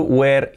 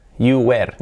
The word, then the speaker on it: were: No se pronuncia you were, no se pronuncia así, W-E-R-E. Se pronuncia, atentos, es were. You were here, you were. Es simplemente el sonido W y la R, were. You were here, were. Con la R muy fuerte, así, you were here. Es casi un diptongo prácticamente, la misma sílaba.